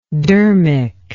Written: dermic

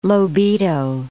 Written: Lobito

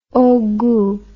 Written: haut gout

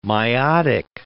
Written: meiotic

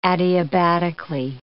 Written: adiabatically

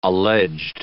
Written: alleged

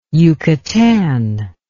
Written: yucatan

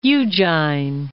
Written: Ujjain

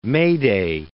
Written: Mayday

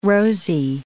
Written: rosy

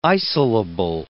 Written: isolable

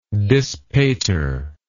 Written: dispater

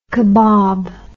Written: kebob

